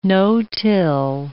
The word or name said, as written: no-till